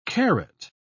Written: karat

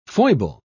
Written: foible

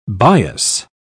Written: bias

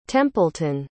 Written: templeton

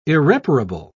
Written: irreparable

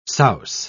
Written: souse

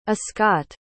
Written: ascott

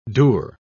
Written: dour

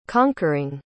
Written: conquering

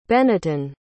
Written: Benniton

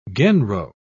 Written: genro

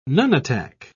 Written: nunatak

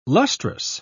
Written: lustrous

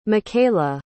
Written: micaella

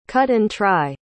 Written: cut-and-try

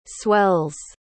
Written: swells

